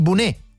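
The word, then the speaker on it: bonet: Il bunè, allora, visto che l'hai citato e parlavamo prima di Piemonte, intanto si scrive con la T, ma non si dice la T, il bunè.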